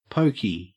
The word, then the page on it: poky: Australian English